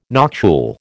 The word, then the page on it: noctule: American English